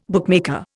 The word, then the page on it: bookmaker: Indian